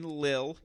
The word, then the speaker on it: Lille: Talk about the action in Lille.